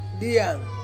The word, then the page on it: ang: Ansus